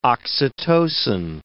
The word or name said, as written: oxytocin